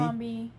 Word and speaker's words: Abercrombie: Abercrombie.